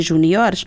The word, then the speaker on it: juniores: Quando dizemos, adaptamos ao português quando no singular pomos um acento em júnior e em sénior, mas no plural é seniores e juniores. – Juniores. – Exatamente, juniores e seniores maiores, o acento é no O, palavra, o acento não pode ir para além dessa sílaba, não é?